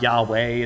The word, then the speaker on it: Yahweh: That is specifically the proper name of God, Yahweh, as we generally pronounce it, YHWH.